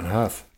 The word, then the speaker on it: Howe: Incubus director John Howe? Anybody know how you say it? – Huff. – John Huff, sorry.